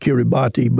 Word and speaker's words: Kiribati: It's spelled K-I-R-I-B-A-T-I. Looks like Kiribati, but it's pronounced Kiribati.